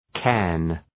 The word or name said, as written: cairn